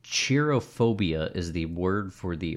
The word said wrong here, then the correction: cherophobia: It must be cherophobia. Cherophobia. – Spell it. – C-H-E-R-O phobia. – Chero. – It's not the fear of Cher. It comes from the Greek word chero, which means to rejoice.